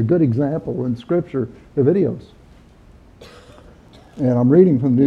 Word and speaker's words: idios: And the first one is idios. I-D-I-O-S. And idios is close to the correct pronunciation.